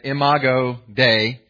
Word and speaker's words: Imago Dei: Imago Dei, that's the phrase that's up there. That's a Latin phrase, and so you all know some Latin now, and that's how you say it, Imago Dei.